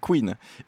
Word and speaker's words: Couine: Donc Faze, Queen, un groupe qui fait dans le garage rock, contrairement à ce que son nom l'indique, ça s'écrit C-O-U-I-N-E, Queen.